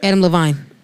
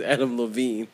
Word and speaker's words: Adam Levine: No, we're going to talk about Adam Levine and how he wild as shit. – Wait, say his name again? – Adam Levine. – His name is Adam Levine.